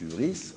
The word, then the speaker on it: hubris: Alors j'ai écrit ici aussi hubris, on prononce hubris, on peut l'écrire hubris.